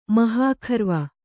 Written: ಮಹಾಖರ್ವ

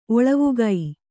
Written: ಒಳವುಗೈ

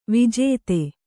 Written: ವಿಜಾತಿ

